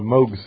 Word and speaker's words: Moog: That's him, and it's a Moog synthesizer, or it should be a Moog synthesizer, because it's named after him. – Dear. – That's how he pronounces it.